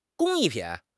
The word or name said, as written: 工艺品